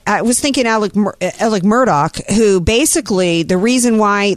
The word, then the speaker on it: Murdaugh: I've been watching the Murtalk, It's Alex Murdaugh, but it's pronounced Alec Murdock, and that's because there's a lot of southernness going on in this trial because it's in South Carolina.